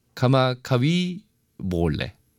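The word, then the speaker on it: 카마카비 몰레 (Kamakawiwoʻole): 이스라엘 카마카비 몰레. 어, 네. 카마카비 몰레. 어, 몰레군요. 어, 그 W를 V로 발음을 하는 거구나.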